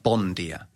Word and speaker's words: bom dia: So just an example of good morning is bom dia, written, but it's actually pronounced bon dia.